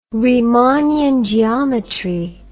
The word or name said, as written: riemannian geometry